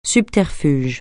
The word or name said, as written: subterfuge